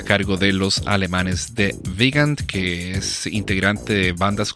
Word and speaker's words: Wiegand: Es de hecho tecladista de la agrupación Toy y de la agrupación Diorama, el, eh, el artista que está detrás del proyecto Vigand, o es que se escribe Vigand con W-I-E-G-A-N-D, por aquello de que quieran buscar en Bandcamp si fue que les gustó este tema llamado Get Informed en su video version.